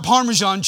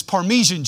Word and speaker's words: Parmesan: It's not Parmesan cheese, it's Parmesan cheese.